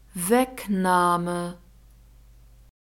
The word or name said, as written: wegnahme